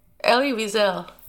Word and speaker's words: Elie Wiesel: And Eli Wiesel started doing... Sorry, how do you pronounce his name? – Elie Wiesel. – Thank you. I knew it couldn't be Wiesel.